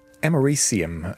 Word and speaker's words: americium: Americium, is that how you say it? – Americium, I think, is the correct pronunciation of that.